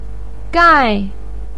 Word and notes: gai4: pinyin

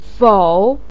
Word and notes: fou3: pinyin

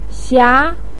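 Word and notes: xia: tone 2

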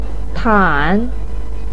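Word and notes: tan: tone 3

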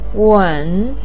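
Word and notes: wen: tone 3

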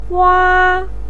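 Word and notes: wa: tone 1

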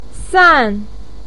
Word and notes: san: 4

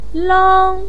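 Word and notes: lang1: pinyin; tone 1

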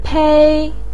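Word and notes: pei1: tone 1

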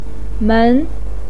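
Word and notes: men2: pinyin; tone 2

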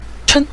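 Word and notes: chen: tone 5

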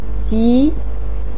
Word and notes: ji: 2

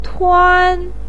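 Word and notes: tuan: tone 1

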